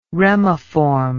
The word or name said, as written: remiform